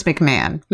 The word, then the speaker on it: McMahon: How do you say that? Vince McMahon. – McMahon? Are you fucking serious? – I'm 100% serious. If there's one thing I know, it's how to say Vince McMahon's name.